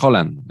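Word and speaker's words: Haaland: Me gusta que digas Holland, así no estoy solo con esa pronunciación. En España es Holland siempre.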